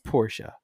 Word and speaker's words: Porsche: When I Googled Porsche pronunciation, and the proper pronunciation, it is Porsche.